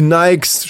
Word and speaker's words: Nike: Die Nikes stehen da und da, dann ist eben, weil die Silbe, Nike sind halt zwei Silben, deswegen wird oft Nikes oder Nike gesagt.